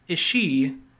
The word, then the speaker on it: Ishii: Kazuyoshi Ishii and Andy Hung. – That's cool. – I-S-H-I-I. I'm going to assume it's Ishii.